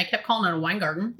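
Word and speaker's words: Weingarten: And just so you know, Wine Garden Rights, in case you want to do some research, is spelled WEINGARDEN.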